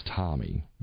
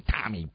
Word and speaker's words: Tommie: And then another one wanted me to use Tommy because he liked the way I spelled it, which is the way I spell it, which is T-O-M-M-I-E, which was my father's name. Yeah, Tommy Bowen.